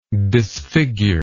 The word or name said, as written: disfigurer